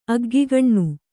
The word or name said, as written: ಅಗ್ಗಿಗಣ್ಣು